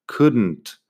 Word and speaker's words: couldn't: Así que eso es bastante sencillo, pero tienes que acordarte de no pronunciar la L nunca en estas palabras. En negación es Igual no tengo ejemplos en la web, pero las formas negativas, wouldn't, couldn't, shouldn't. La L no suena, la N y la T al final sí.